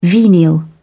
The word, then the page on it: venial: British English